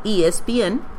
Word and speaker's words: ESPN: Entonces hace 2 años el director de programación de ESPN Deportes, ESPN, se pronuncia en inglés, ESPN, en español, me pidió que le hiciera los servicios públicos para las radios.